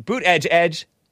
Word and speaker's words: Buttigieg: according to Pete Buttigieg.